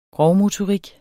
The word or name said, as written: grovmotorik